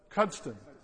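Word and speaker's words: Cuddesdon: She is at, remind me of the place that she's at, Cuddleston. Cudston. I always mispronounce it.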